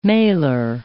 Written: malar

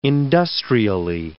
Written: industrially

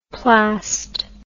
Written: plast

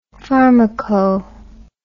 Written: pharmaco

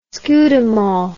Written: Scudamore

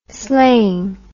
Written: sleighing

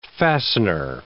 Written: fastener

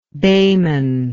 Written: behmen

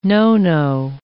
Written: no-no

